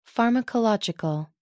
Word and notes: pharmacological: American English

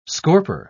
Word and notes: scorper: British English

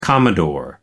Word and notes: commodore: American English

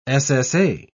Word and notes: ssa: British English

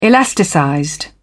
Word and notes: elasticized: British English

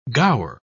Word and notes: gaur: British English

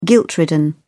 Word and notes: guilt-ridden: British English

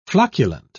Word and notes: flocculent: British English

